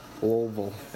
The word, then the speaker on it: Louisville: Most people are like, how do you pronounce Louisville? – Louisville. – It's, you just run it all together.